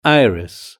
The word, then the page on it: iris: British English